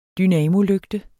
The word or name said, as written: dynamolygte